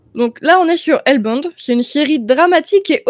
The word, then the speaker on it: Hellbound: Ça se prononce Hellbound. Hellbound.